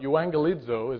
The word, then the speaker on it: euangelizo: It's called euangelizo, is how it's pronounced in Greek.